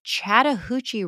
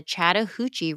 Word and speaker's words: Chattahoochee: They built a dam on the Chattahoochee River. – Chattahoochee. – That's it. You're saying it wrong. It's not Chattahoochee.